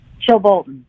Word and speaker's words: Chilbolton: Chilbolton.